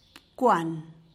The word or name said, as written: bkwan